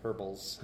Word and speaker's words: herbals: Or apparently, as the British say, herbals. – Herbals, yes. There's an H in there. You're supposed to pronounce it.